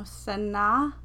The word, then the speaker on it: Sanaa: No, Sanaa? – Yes, that's how you say it. You said it right twice.